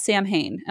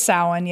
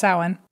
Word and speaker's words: Samhain: Now, I need to be clear here that I said it wrong last time. And I said it wrong because I asked somebody specifically how to say it. And they said, oh, it's pronounced Samhain.